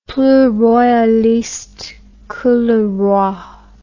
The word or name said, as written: plus royaliste que le roi